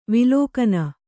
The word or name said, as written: ವಲ್ಗನೆ